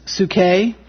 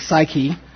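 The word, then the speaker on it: psuche (ψυχή): The first one is psuche, in the Greek. That's a Greek pronunciation. We pronounce that probably psyche, but psuche in the Greek.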